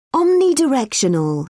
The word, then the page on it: omnidirectional: British English